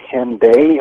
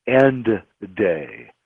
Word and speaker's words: Hendaye: I know it's pronounced Henday. How is that spelled? – H-E-N-D-A-Y-E. And if you're speaking, you know, old French, the word, it would be actually pronounced end day.